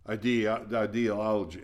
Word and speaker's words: ideology: Homosexuality, trans, woke ideology. How do you say that word? Ideology. Thank you. I had the accent in the wrong syllable.